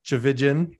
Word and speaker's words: Tchividjian: They are two incredible nonprofit leaders who are running an organization called Every Mother's Advocate, joined by Charlee Tchividjian.